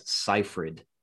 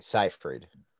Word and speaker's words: Seyfried: So we have talked about this particular actress on the podcast before, and I have always pronounced her name Amanda Seyfried. And I was noticing the last time we talked about her on the podcast, Doug pronounced her name different than I did. So today I looked up how she actually pronounces her name, and she says Seyfried.